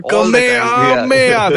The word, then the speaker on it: Kamehameha: Yeah, Kamehameha. That's how they say it in the anime.